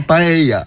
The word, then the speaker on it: paella: Pisze się paella, czyta się paella.